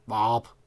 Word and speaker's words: Vorup: Der ligger der en by, som hedder V-O-R-U-P, som man ikke skal udtale Vorup, som var det, jeg, prøvede, jeg gjorde, da jeg flyttede hertil. Men i stedet skal udtale sådan lidt ligesom en herningenser, der bøvser. Varup.